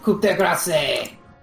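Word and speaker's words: coup de grâce: Pronounced coup de grace. – Coup de grace. – It's only coup de grace if it comes from the right region of France, otherwise it's just sparkling murder.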